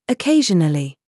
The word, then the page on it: GENRE: British English